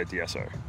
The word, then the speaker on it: D'Esso: The easiest way to pronounce it is the Aussie way. So just say the letter D, the letter S, and the letter O all together really quickly. Just say D'Esso.